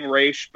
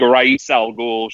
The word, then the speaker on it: Ra's al Ghul: I've started saying Raish, but it's technically neither of them are correct because neither of them are actually like correct, like Arabic pronunciation. So it's like, it's kind of a moot point. It's like Ra's al Ghul should be the correct pronunciation, but nobody says that.